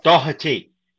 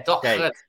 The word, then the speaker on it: Doherty: And then we've heard from Matt Do- uh, Doherty. I, hope I say it right. – Doherty. – Yeah, Doherty. Doherty.